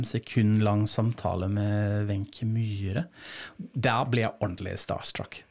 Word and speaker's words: Myhre: Myhre. Nå har jeg lært meg å uttale etternavnet riktig også, ellers blir du sur. fordi jeg, pleier å si som mange tyskere Wenche Myrre.